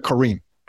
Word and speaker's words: Corrine: Hey, we are starting this podcast with Corrine Sandler. And I want to make sure I'm pronouncing your name properly. Am I pronouncing it properly? – You are, Corrine. Yes. – Okay, Corrine, because you're born in South Africa.